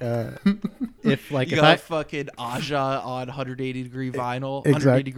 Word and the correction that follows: Aja: Asia, it's pronounced Asia.